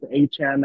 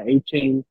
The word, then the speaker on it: Achane: Achan, I said his name wrong a few weeks ago. It's Achan, not Achane.